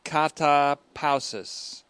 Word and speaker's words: katapausis: Katapausis, or katapausis, the accent on the second syllable.